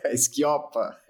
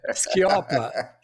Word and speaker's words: Schioppa: Quase. Esquiopa. Esquiopa. Faltou, faltou puxar, puxar de...